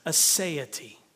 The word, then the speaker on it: aseity: And the word is this: aseity. A-S-E-I-T-Y, if you're wondering how to spell it.